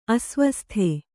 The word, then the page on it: ಅಸ್ವಸ್ಥ: asvastha